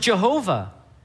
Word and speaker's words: Jehovah: We pronounce it Yahweh. Some people pronounce it Jehovah.